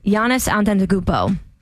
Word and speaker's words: Giannis Antetokounmpo: I had to spell Giannis spelled phonetically just so I wouldn't really F it up because it starts with a G.